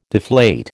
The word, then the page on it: deflate: American English